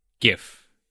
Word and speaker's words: GIF: It's definitely GIF. You don't pronounce it like a J, you pronounce it like a G.